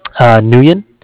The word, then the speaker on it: Nguyen: Nguyen. It's spelled N-G-U-Y-E-N.